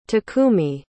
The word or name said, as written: takumi